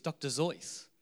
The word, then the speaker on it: Seuss: I found out that the correct pronunciation of Dr. Seuss is Dr. Zeus. Did anyone know that? Dr. Zeus, it rhymes with, is that right?